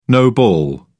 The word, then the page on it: no ball: British English